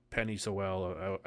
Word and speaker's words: Penei Sewell: Yeah, for somebody to move up uh, to two and take Zach Wilson and take Justin Fields and take Penei Sewell. I, I, think I said it wrong. – You said it right.